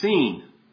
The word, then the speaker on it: Sin: Most likely seen. It's interesting. It's spelled S-I-N in English. Sin. Seen.